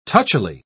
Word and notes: touchily: British English

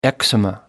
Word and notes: eczema: American English

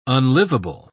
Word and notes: unlivable: British English

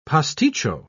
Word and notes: pasticcio: British English

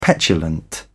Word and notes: petulant: British English

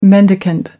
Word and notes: mendicant: American English

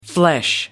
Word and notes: flesh: American English

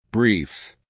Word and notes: briefs: American English

